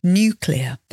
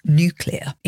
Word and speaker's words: nuclear: That's N-U-C-L-E-A-R, nuclear. That's the British English pronunciation, of course, nuclear. In US pronunciation, it's more like nuclear. I can't even say that properly, but nuclear.